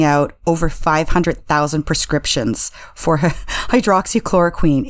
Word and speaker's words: hydroxychloroquine: Another thing that I think came out of this too was just President Trump had repeatedly and consistently touted the effectiveness of hydroxychloroquine, I think is how you pronounce it. – Hydroxychloroquine. – Thank you.